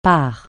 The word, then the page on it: part: francais